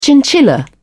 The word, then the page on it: chinchilla: British English